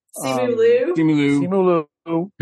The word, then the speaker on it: Simu Liu: You've got Sima. I'm going to get the name wrong. – Simu Liu.